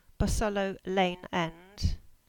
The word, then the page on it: Bosullow Lane End: English